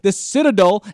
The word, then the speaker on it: The Citadel: The Citadel is not used to...